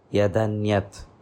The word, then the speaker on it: yadanyat: This yat anyat, when it joins, we pronounce it as yadanyat, yadanyat, yadanyat.